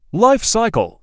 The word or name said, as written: Life cycle